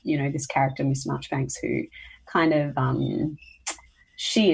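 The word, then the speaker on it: Marjoribanks: Um, it's called Miss Marchbanks, but it's spelt Miss Marjorie Banks, but it said Marchbanks mm.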